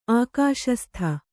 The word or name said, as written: ಆಕಾಶಸ್ಥ